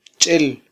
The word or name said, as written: ch'el